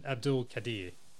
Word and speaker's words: Abdul Qadir: All right, Safraz Nawaz and Abdul Qadir. – I think you said those right. I'm pretty sure.